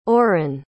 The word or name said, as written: oren